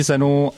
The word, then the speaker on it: Xen: Jak já tomu teda rozumím, tak Zen se vyslovuje Zen, ačkoliv se píše Xen, a já jsem tomu až dovčera říkal Xen, a asi tomu budu říkat dál Xen.